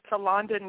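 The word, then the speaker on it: Tholanda: It is Talonda Newborn, and let me spell it for you, please. It's T-H-O-L-A-N-D-A, and my last name is Newborn with an E on the end, N-E-W-B-O-R-N-E.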